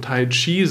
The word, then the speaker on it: Tai Chi: Äh, das hat übrigens, dass man Tai Chi sagt im Chinesischen, hat damit zu tun, dass die chinesische Sprache insgesamt eher weicher ist als das japanische, wo man dann mit dem K, das Ki geschrieben, so ein bisschen härter ist.